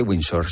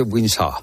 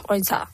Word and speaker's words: Windsor: Porque hemos aprendido que no se dice Windsor, se dice Windsor. – Windsor.